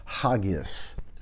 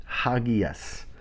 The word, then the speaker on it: hagios: Okay, the term holy is actually pronounced hagios, hagios.